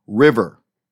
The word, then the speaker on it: RIV3R: Sila po ang bandang River, and that's spelled RIV3R.